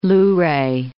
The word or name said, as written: Luray Caverns